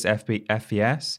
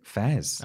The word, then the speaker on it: FES: I have just learned from you that it's just pronounced FES. I had to talk about that game in a list. And oh, yeah. – I Googled it. I was like, is this FES? Mm. And loads of people on YouTube are calling it Fez.